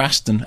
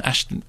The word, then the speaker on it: Ashton: Is it Ashton or Aston? Ashton.